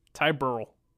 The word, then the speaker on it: Burrell: Ty Burrell.